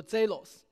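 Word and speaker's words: zelos (ζῆλος): 在希腊语里面，这个词读作 zelos。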